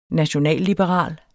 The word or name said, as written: nationalliberal